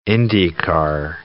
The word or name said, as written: Indy car